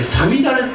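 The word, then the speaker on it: 五月雨: さみだれ」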